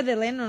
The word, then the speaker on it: Delano: Pero se dice Delano, ¿no? – Ah, yo no sé. – No, Delano. – Ah, yo no sé. – Se escribe así, digo, porque a mí me corrigieron un montón de veces.